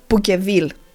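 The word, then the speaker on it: Pouqueville: διπλωμάτης και πυρηγητής Φρανσουά Πουκβίλ, Πουκεβίλ τον λένε και καλά στα ελληνικά, Πουκβίλ προφέρεται τέλος πάντων,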